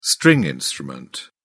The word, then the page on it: stringed instrument: British English